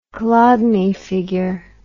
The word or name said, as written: Chladni figure